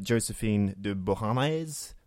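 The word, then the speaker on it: Joséphine de Beauharnais: And the film shows the revolution, shows his rising through the ranks, how he made enemies, his courtship with Josephine de Beauharnais, that's how you pronounce her name.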